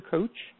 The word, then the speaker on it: Koch: The 80-20 Principle, The Secret to Achieving More with Less by Richard Koch, K-O-C-H, that's how he pronounces it.